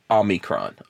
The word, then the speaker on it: Omicron: Omnicron, the Transformer from South Africa. – Yeah, let me say this right. I mess it up. Omicron.